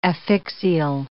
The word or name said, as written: affixial